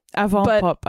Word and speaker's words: avant pop: It's like from avant-garde. – Yeah. Avant pop, I think. – Avant pop. Yeah. Is it that way? – I think that's it. That's how I would pronounce it.